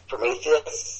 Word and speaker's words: Prometheus: But, going back to the trailer thing, I then saw the full trailer for, this is where I'm going to embarrass myself, Prometheus. – Yep. – Is that the right pronunciation? – That's it.